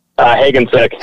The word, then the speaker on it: Hagensick: Hagensick.